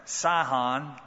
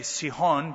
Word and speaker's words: Sihon: That's the correct pronunciation. Uh, reads to us like Sahon, but I, I'd prefer to say Sihon because that's the pronunciation.